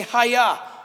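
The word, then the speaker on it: haya (היה): Yahweh from the Hebrew verb haya. Everybody say haya. Brilliant. It's the Hebrew verb to be. It sounds like a karate chop. Haya. Or waving someone across the street. Haya. Sounds a lot like that.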